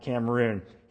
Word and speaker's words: Cameron: Listen, Richard Cameron, some pronounce it Cameroon, he was one of the Covenanters, a leader.